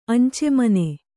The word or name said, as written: ಅಂಚೆಮನೆ